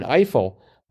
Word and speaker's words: Ifill: We also had in that area 51 down journalist Gwen, and I knew it was Gwen Ifill, but I wasn't sure how to spell that. – Oh. – Turns out it's just like it sounds, Ifill.